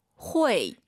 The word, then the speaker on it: 会: 후이가 아니라 발음 다시 들어볼게요. – 회. – UI 사이에 에 넣어야 돼요.